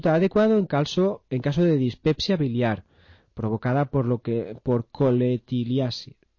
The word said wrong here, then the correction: colelitiasis: Lo he dicho bien, ¿no? Colelitiasis, así sí.